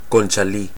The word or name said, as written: Conchalí